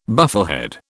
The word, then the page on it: bufflehead: British English